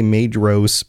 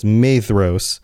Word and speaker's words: Maedhros: My only qualm is the way you pronounce names with the DH in them, like I say Maedhros, but you're saying the DH is pronounced with like a TH, so it's Maedhros.